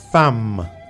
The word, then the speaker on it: femme: Mujer se dice en francés femme. Femme. Pero se escribe, mira bien, ¿eh? Se escribe F-E-M-M-E. Yo repito, femme. Se escribe F-E-M-M-E.